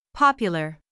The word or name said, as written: popular